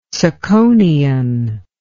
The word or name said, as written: tsakonian